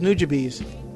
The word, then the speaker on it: Nujabes: Nujabes. Is that how you pronounce the guy's name? – I thought it was Nujabes.